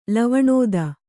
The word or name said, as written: ಲವಣೋದ